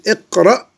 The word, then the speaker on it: اقرأ: يعني الهمزة التي في أول الفعل المضارع حينما يسبقها كلام لا تنطق لكنها تكتب، فنقول اقرأ واكتب، فالهمزة هنا لا تنطق.